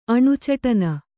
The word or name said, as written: ಅಣುಚಟನ